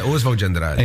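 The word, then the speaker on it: Oswald de Andrade: Yeah, Oswald G. Andrade.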